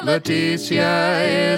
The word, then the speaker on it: Letizia: L-E-T-I-Z-I-A. – Do I pronounce that the way? – Leticia is the queen's first name.